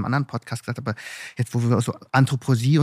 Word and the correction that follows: Anthroposophie: und so, nee, ich habe es falsch ausgesprochen, aber ich spreche Sachen immer falsch aus. – Genau. – Du lachst dich jetzt schon kaputt, ja. – Aber, Anthroposophie.